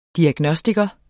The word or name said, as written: diagnostiker